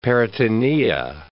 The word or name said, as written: peritonea